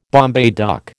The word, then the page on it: Bombay duck: American English